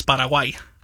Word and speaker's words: Paraguay: It's the Spanish pronunciation is Paraguay.